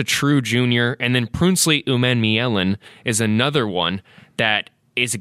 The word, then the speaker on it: Umanmielen: And say it one more time, because I have to learn it. – Umanmielen. – Umanmielen. – U-M-A-N-M-I-E-L-E-N. – Prinsley Umanmielen.